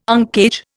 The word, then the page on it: uncage: Indian